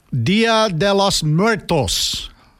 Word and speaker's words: Dia de los Muertos: Dia de los Muertos.